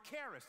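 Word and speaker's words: charis (χάρις): And it's the word charis. Does this sound like an English word, charis? Which one? Sounds like care, keep going. It's spelled C-H-A-I-R-S.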